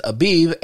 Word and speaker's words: Abib: Um, he says Abib, A B I B, is the Hebrew word for spring.